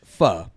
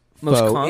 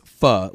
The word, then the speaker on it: pho: The generally accepted way to say foe is F-U-H, pho. – Oh, the generally accepted? – Yes. – What's the actual way to say it? – The most common way to pronounce foe in Vietnam is pho, like duh. – Mm. – Some regions pronounce it more like foe.